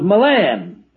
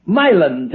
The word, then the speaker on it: Milan: Several years passed, and I met the pastor of the Baptist Church of Milan, Tennessee. And I learned that they pronounced the name of the city with the accent on the first syllable.